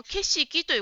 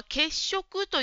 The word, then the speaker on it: 景色: それから答えの文の景色という言葉ですが、血色と言ってるように聞こえました。これは景色と読みます。